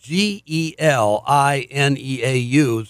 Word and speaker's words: Gelineau: But just let me mention that our guest, whose name is Bill Gelineau, that's spelled G E L I N E A U, sounds French to me, Gelineau.